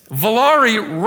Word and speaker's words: Volare: Volare rhymes with Ferrari, but that's as close as it gets, okay?